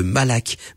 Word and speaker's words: malakh: le malak. Malak qui, qui s'écrit M-A-L-A-K-H.